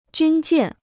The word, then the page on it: 军舰: jūn jiàn